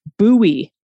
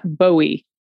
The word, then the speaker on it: Bowie: I'm not sure if this very minor correction has been submitted, but just in case, Bowie, MD, is pronounced Bowie, not Bowie. There we have it. – Like the knife.